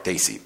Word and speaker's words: Desi: I needed to get on solid ground on what it meant to be a Desi. Am I saying that correctly? – Desi.